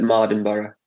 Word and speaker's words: Mardenborough: So I like to, when tell me how to say your name, how you say it properly. – It's Jan Mardenborough.